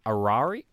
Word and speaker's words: Arai: Did you know that, yeah, all of the Red Bull team mandates that their drivers use a specific helmet manufacturer in Arari? Am I pronouncing that correctly? – I think you are.